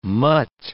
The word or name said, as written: mutt